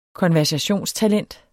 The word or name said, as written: konversationstalent